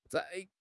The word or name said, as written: 宰